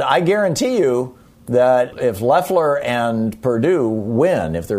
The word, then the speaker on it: Loeffler: And right now, David Perdue and Kelly Loeffler, I guess it's pronounced. I keep wanting to go with the German pronunciation.